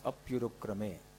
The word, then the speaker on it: Apyurukrame: Then Nirgrantha, there is Api and Urukrame. But when you combine them, then you pronounce it as Apyurukrame. Nirgrantha Apyurukrame. Because the two words get combined.